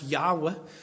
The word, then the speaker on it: Yahweh: That's what Jehovah, in Hebrew, it would be pronounced Yahweh.